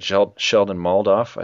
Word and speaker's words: Sheldon Moldoff: And yeah, yeah, that, that's uh, I said earlier when I started the summary is that Sheldon Moldoff, I think how you pronounce his name.